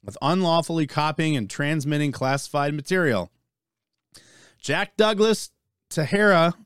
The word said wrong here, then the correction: Teixeira: is that how you say it? Tejera? – Teixeira.